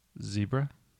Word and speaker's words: zebra: Zebra. – False, sir. It is zebra.